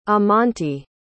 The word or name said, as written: amante